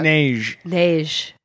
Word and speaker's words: Neige: Neige.